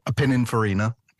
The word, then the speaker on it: Pininfarina: Pininfarina. – Pininfarina.